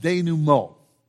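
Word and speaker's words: dénouement: But in Act 3, there is this thing that, and I hope I don't butcher the French, but I believe it is pronounced denouement.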